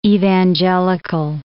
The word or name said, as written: evangelical